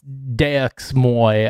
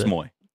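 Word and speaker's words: DeuxMoi: Is Dumas, Dumois, it's Deux Moy, I think is how Miles pronounced it. – Yeah, Deux Moy.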